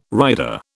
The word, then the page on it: rider: British English